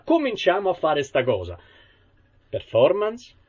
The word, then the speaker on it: performance: Se dico performance, si pronuncia performance. Non si pronuncia performance, perché non sta né in cielo né in terra.